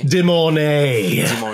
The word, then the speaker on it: De Mornay: Rebecca Dormay, I can't say her name for the life. – Rebecca De Mornay. – De Mornay.